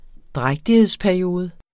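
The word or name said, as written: drægtighedsperiode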